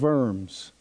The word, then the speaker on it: Worms: It all culminated in his dramatic stand at the, as Jeff has told me how to pronounce this, Diet of Worms. Looks like Diet of Worms, which could be misunderstood.